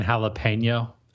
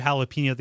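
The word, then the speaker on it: jalapeño: Well, loves kicking jalapeno. – Oh man, if I had a dime for every jalapeno that he's shot.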